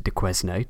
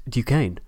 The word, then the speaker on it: Duquesne: I'm usually quite good with French, but apparently Duquesne is not pronounced Duquesne, despite being spelt like that. It's actually pronounced Duquesne, so my apologies.